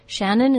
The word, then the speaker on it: Shannon: Shannon is spelled with three N's in total, S-H-A-N-N-O-N.